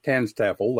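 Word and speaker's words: TANSTAAFL: I was thinking that the first I was exposed to a little phrase called, I think it's pronounced, Tanstaffel, that's how I've always said it, stands for there ain't no such thing as a free lunch.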